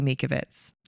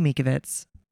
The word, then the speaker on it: Mikovits: With Mickey Willis and Judy Mikovits. – Yes. – I hope I'm saying that correctly. – I believe you are. – Judy Mikovits.